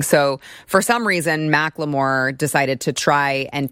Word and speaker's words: Macklemore: Now, I was just recently informed that it's spelled Macklemore, but you're supposed to call him Macklemore. So it sounds ridiculous, but that's his name.